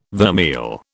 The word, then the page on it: vermeil: British English